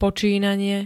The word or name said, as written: počínanie